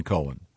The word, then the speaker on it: Cohen: This that's confusing because not when it's spelled C-O-H-E-N. – It's definitely Cohen.